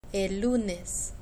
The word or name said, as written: el lunes